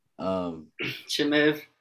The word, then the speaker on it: Chimaev: Chimaev.